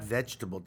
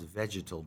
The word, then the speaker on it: vegetal: In the world of reds, you can say, the oldest color is the terracotta, the and it's not vegetable dye, it's pronounced vegetal dye. I was corrected by our professor.